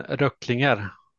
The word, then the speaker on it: Röcklinger: Björn Röcklinger. Vad säger Tobbe om procentsatsen då? Är den rimlig? – Vad skrattar du åt eh. nu? Det var perfekt uttal? – Ja, det var jättebra.